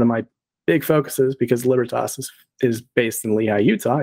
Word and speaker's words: Libertas: It's actually pronounced Libertas, but the phonetic spelling is L-I-B-E-R-T-A-S. It's Libertas. You can find me there, or you can find me on Twitter.